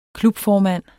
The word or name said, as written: klubformand